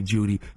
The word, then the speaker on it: Jeudy: I think it's extremely stupid because your last name is spelled Jeudy, J-E-U-D-Y.